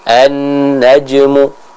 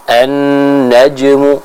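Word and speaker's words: an-najmu (النجم): huruf jim yang sukun juga sama, dia disifati dengan sifat qalqalah, sehingga adanya suara yang harus dipantulkan dalam pengucapan huruf jim tersebut. Contoh, an-najmu, an-najmu. Namun sekali lagi, karena huruf jim di sini dia dihukumi sebagai qalqalah sughra, maka pantulannya jangan sampai terlalu kuat sehingga apa?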